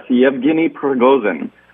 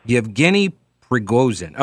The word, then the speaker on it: Yevgeny Prigozhin: Okay, and so how then do we pronounce this guy's name who is the leader of the orchestra? – Yes, Yevgeny Prigozhin. – Yevgeny Prigozhin.